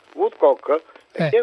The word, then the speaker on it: Woodcock: Come si pronuncia? – Woodcock. È una persona molto intelligente e anche simpatico.